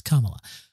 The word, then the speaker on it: Kamala: They say Kamala. – Kamala. Yes, Kamala. And I spend most of my days when I'm watching these things and listening to these things, every time they say it wrong, me going, Kamala.